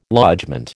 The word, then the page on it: lodgement: American English